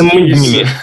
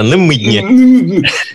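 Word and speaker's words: Намедни: А если это какой-нибудь условно Парфенов э, с намеднями, да, или Побединский... Намыднями. правильно говорить. Называй, пожалуйста, правильно. Намыдни.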